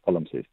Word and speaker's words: palimpsest: Palimpsest. – Yes, there we go. Palimpsest.